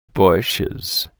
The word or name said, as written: bushes